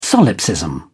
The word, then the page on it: solipsism: British English